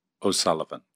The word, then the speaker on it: O'Sullivan: And my full name is Fiachra O'Sullivan.